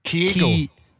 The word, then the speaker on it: Kyogo: I got that wrong. Kyogo. – Kyogo. – I'm leaving all this in. – So Goldson's got his man Kyogo, right? – Well done, yes.